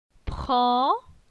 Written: Pran

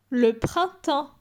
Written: Le printemps

